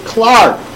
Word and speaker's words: Clerk: Even though it's spelled C-L-E-R-K, I've been informed by Donna Montrezza that it's pronounced Clark. So I apologize to him, and I'm going to stick with the initials J-C-M.